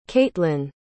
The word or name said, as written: caitlyn